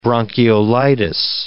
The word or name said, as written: bronchiolitis